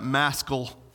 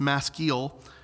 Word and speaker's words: maskil: Some people pronounce that maskil. It's maskil.